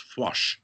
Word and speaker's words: Foix: In Catalan and in Occitan, that's pronounced Foix, and literally means faith.